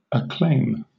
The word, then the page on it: acclaim: British English